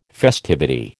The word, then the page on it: festivity: American English